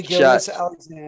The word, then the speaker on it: Gilgeous-Alexander: Hey, Gilgeous-Alexander.